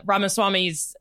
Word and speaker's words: Ramaswamy: Though, your point about Ramaswamy's Rise. – Growing. Is that his name? Did I say it wrong? – Yeah, Ramaswamy.